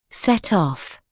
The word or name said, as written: set off